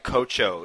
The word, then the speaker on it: Cocio: In this panel ever drink Cocho? It's spelled like COCIO, and it's like a glass bottle.